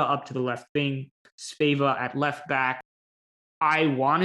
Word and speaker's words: Sveva: And I just want to say real quick everyone says Sofie Svava. And I thought that was how you pronounce it initially. And when I say everyone, the commentators, everyone says Svava. I'm just letting everyone know PSA, it's Sveva. And I know this because I found a video of her saying her name herself.